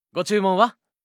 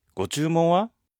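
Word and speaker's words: Го-чу-мон-ва (ご注文は): Го-чу-мон-ва? – Го-чу-мон-ва означает «что бы вы хотели».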